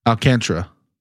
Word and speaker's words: Alcantara: Is it Alcantara? How do you say that? – Alcantara.